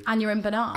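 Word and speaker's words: Aneurin Barnard: Anurin Bernard.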